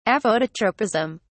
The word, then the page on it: aphototropism: British English